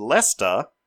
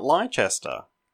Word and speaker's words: Leicester: Thank you to Morgan, who wrote in to tell me that it's pronounced Leicester, not Leicester.